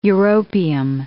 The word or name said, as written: europium